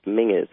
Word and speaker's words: Menzies: By the way, if you see Menzies written down, it's pronounced Mingus, everybody.